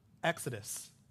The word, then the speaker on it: exodus: And the word that's translated departure, do you know how it's pronounced in Greek? Exodus.